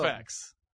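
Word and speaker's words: Aciphex: Acifex. It is now, I'm not mispronouncing that.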